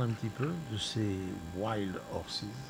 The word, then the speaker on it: Wild Horses: Et puis dernier groupe qui va clôturer ce 39e festival, c'est Wild Horses. C'est comme ça qu'on prononce ? – The Wild Horses. – J'étais bon ou pas ? – The Wild Horses. – Presque. Wild Horses.